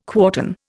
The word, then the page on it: quartan: Indian